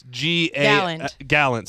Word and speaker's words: Gallant: Okay, his name is Joseph Gallant. – Gallant, sorry. G-A-L-L-A-N-T.